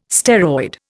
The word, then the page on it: steroid: Indian